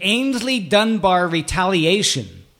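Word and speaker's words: Aynsley Dunbar Retaliation: The Ainsley Dunbar Retaliation.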